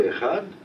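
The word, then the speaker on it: Echad (אחד): Il faut allonger la prononciation de Echad en appuyant le dalet, mais à condition de ne pas avaler le chet.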